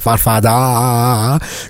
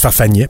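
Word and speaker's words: farfadet: Est-ce que ça me met comme image dans la tête les farfagnets qui font de la sécurité ou les farfadas, comme on savait pas trop prononcer à la commission? Ben, ça se prononce farfagnet, premièrement.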